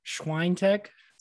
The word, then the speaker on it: Swiatek: And then I go Swiatek. Is that how we say her name now? – Swiatek. – Swiatek.